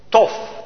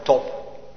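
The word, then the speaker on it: tof (טוב): is het Hebreeuwse woord tof. T-O-B, met een streepje, dan spreek je het uit. Zacht, althans, als een F. Kan ook hard uitgesproken worden, top.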